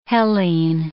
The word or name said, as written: Hellene